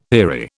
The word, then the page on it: eerie: British English